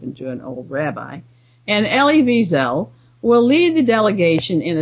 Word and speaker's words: Elie Wiesel: They will be joined by a number of Holocaust survivors from North America, headed by Nobel laureate and survivor Elie Wiesel. His name should actually be pronounced Elie Wiesel. Elie Wiesel, but not with a big accent on the last syllable, which people tend to put on it.